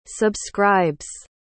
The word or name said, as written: subscribes